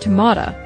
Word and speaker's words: tomato: But what you didn't say was whether it's pronounced tomata, like my mother says it, because that's a Virginia pronunciation.